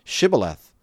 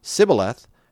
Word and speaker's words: Shibboleth: Then they said to him, "Say Shibboleth." If he would say Sibboleth and could not pronounce the word correctly, they grabbed him and executed him there right at the fords of the Jordan.